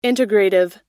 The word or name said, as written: integrative